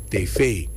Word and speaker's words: tv: En zou je zeggen van, hey, luister dan, dat ding spreek je uit, tv. – Okay. – Daar zit die klemtoon.